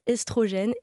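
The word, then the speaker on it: œstrogène: Donc le « e » dans l'eau, quand c'est suivi par une consonne, se prononce « é ». On devrait dire « estrogène »,« ésophage », etc.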